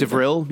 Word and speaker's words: Devrel: I'm going to say it like Devrel. Yeah, Devrel. It's like DeVille, but there's an R in there somewhere, too.